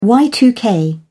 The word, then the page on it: y2k: British English